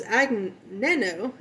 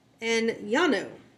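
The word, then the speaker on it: Agnano: Our 34th entry is Enyano. So I said it was Agneno, because that's how it's spelled. But it's pronounced Enyano.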